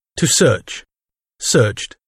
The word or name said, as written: search